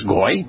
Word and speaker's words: Goyim: Everybody knows Goyim. the Hebrew term. It means non-Jewish, non-Yehudim, non-Israelite. And the wah is the produces the O sound.